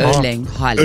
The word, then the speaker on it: Erling Haaland: Erling Haaland.